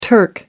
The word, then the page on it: turk: American English